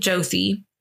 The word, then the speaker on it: Jyoti: I've heard it pronounced Joti, but it seemed like more often it was pronounced Jothi. It's, I think, Jothi Singh. So I'm going to go with Jothi.